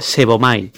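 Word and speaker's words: sebomai (σέβομαι): Y la segunda derivación de la palabra piadoso es la 45-76 en griego, que se pronuncia sebomai, que significa adorar.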